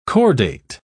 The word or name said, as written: chordate